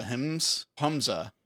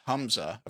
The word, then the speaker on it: HMZ: The gelatinous bodies called the Humza is how that's pronounced. – Yeah, HMZ is how it's spelled. – Yeah, HMZ, how it's spelled, pronounced Humza, according to the book.